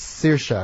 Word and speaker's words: Saoirse: Saoirse. – Because it looks like Saris, but it's Saoirse, and my wife is always, saying this is what it is, because I'll come up with some other pronunciation this wrong.